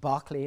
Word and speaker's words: Berkeley: One, for example, Lord Berkeley, which is spelt Berkeley, as in our college, pronounced Berkeley in England.